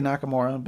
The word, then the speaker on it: Nakamura: Did I say that right? – Yep. So it's Shinsuke Nakamura, but, you know, not far off at all.